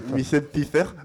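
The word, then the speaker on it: Michelle Pfeiffer: Ou Batman, le défi, en 92, avec Michel Pfeiffer. J'arrive pas à prononcer. – Michel Pfeiffer.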